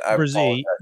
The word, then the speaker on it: Brisé: And for different reasons, to Josh's point, Van Ness and Brisé is that how you say it? – Brisé, yeah. I made the mistake of pronouncing it like you pronounced it. That blew up right in my face.